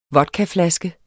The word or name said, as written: vodkaflaske